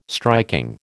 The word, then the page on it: striking: American English